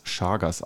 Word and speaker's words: Chagas: So genau habe ich nicht geguckt, stand nur direkt dabei und ich wollte halt wissen, wie man dieses Chagas ausspricht, dieses Chagas Google hat es Chagas ausgesprochen. Ja, auf jeden Fall die Raubwanze, also im Bett.